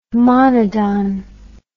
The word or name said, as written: monodon